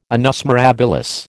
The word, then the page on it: annus mirabilis: American English